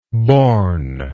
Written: bournless

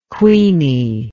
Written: Queenie

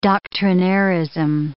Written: doctrinairism

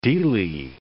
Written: dele

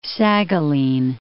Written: Saghalien

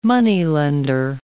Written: moneylender

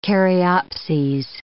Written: caryopses

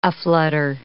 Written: aflutter

